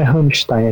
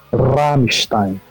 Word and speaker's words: Rammstein: Não, não é, não é Rammstein, é Rammstein. – É, tem, tem razão, tem que puxar CR, eu sempre esqueço no alemão.